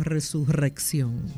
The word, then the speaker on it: resurrección: Claro, perdón, sí, a todo aquel que en algún momento he ofendido por no haber dicho resurrección. – Ah, pues sí, así es. Así está escrito. Y la R, claro, al inicio de una palabra es una sola, que se escribe. El sonido R.